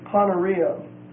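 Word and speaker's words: Poneria (πονηρία): The next word is the word Poneria. P-O-N-E-R-I-A. P-O-N-E-R-I-A. It's a long E. The word means wickedness. Comes from the word poneros, P-O-N-E-R-O-S.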